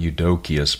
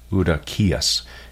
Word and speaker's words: Eudokias: Not too bad, but the correct pronunciation is not Eudokius, but rather Eudokias. And you see that the emphasis there is rightly placed on the last syllable because of the accent mark that is there over the iota, that is the ninth letter of the Greek alphabet.